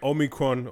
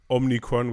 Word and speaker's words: Omicron: Om- the Omicron or Omnicron, which is not how you say it.